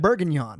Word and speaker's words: bourguignon: That's not bouillon. – It's how did you say it? – Bourguignon. – Bourguignon. Okay. – It's beef bourguignon. French.